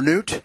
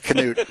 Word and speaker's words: Knut: In December of 1872, his father, Knut, okay, that's probably not pronounced right, is it? Knut or Newt? – Hey, listen, I would have said Newt, but you and your Knut are fine. – Okay, I'm going to stay with Knut.